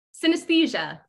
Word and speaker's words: synesthesia: Synesthesia. – Synesthesia.